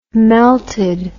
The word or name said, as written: melted